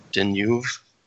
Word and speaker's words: Deneuve: It's Deneuve. Deneuve.